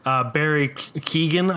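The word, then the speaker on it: Keoghan: Barry Keegan, I believe is how it's pronounced.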